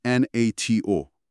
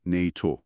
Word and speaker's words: NATO: مثلا تو اخبار هیچ وقت نمیشنوید بگن NATO. همیشه میگن نیتو.